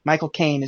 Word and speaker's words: Michael Caine: How do you say his name correctly? Just say the words "my cocaine" very slowly. My cocaine. – Toys,